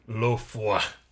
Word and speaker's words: l'eau froide: l'eau froide.